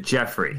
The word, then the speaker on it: Geoffrey: I pronounce it Geoffrey. – Geoffrey. – Like Geoffrey Giraffe from Toys R Us.